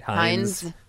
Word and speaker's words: Heinz: So much is now the Heinz has these. Did I say that right? – Heinz.